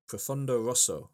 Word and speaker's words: Profondo Rosso: It's in Italian, it will be pronounced Profondo Rosso. – Mm. – Is that okay? – Yeah, perfect.